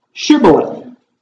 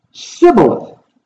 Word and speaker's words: Shibboleth: If he said no, then they would say to him, "Then say, Shibboleth." And he would say, "Sibboleth," for he could not pronounce it right.